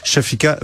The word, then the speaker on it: Shofika: Donc, euh, mon prénom, c'est Shofika, ça fait Shofika.